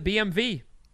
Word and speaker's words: BMW: They don't pronounce it BMW over there, wherever there is. Germany? – Germany. Mm-hmm. Right. Belgium. – They pronounce it BMV.